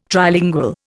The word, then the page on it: Trilingual: Indian